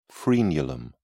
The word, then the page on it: frenulum: British English